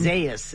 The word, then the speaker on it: Isaias: Isaias is what the pronouncer says. – It seems like it's Isaias, but I remember it being said a different way.